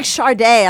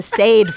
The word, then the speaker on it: Sade: It's like Sharday. I called her Sade for years.